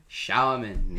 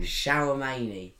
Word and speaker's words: Tchouaméni: Aurelien Tchouaméni. – Tchouaméni. – Shower, and then Manny. – Yeah. – Tchouaméni. – Tchouaméni. Nice. – Tchouaméni. Yeah. Shower many.